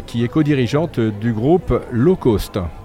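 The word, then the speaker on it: L'Eau Cost: Alors Low Cost, pour nos auditeurs, je précise que c'est L' e comme l'eau, hein, et cost comme le, le coût en anglais, hein, c'est bien ça. Hein. – Tout à fait. – Et ça se prononce bien low cost.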